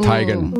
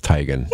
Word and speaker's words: Teigen: John Legend and Chrissy Teigen. – Ooh. – Teigen. – Ooh. Her name's Teigen? – According to her social media, it's Teigen. – No. Yeah. We've been saying it wrong forever.